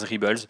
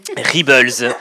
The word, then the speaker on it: Rebels: Rebels, et euh, Rebels, je vais le prononcer correctement, et euh, Rebels, et euh, aussi la fin d'ailleurs euh, de Clone Wars, si je ne dis pas de bêtises.